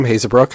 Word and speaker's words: Haezebrouck: My name's Adam Hazebrook.